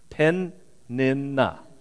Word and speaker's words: Peninnah: And Penaniah, I'm not probably saying that right. You know, you should always practice these before you do. And I just read, as I read it, I thought, that doesn't sound like Penaniah. Peninnah. I've always said her name wrong.